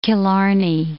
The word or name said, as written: Killarney